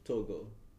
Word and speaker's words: Togo: Yeah, I moved from West Africa, from a little country called Togo. – Togo. – West Africa. It's spelled like to go. So. – To go, but to Togo.